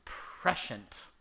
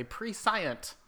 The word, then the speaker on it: prescient: I want to say prescient, but it's not prescient. It's prescient.